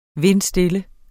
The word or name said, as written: vindstille